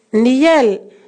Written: niel

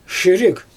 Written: ʃirig

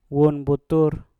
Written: wun butur

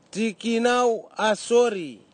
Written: tikinau asori

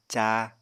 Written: cáa

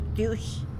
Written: btyuix

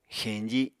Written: xeñji

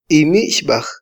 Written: imixbaj

